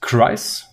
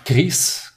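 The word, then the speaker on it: Kryze: Ähm, Bogatan, wie spricht man das aus? Kreis? Bogatan Kries. Kries, Kreis. Okay.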